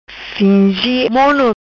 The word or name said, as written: Fingi Mono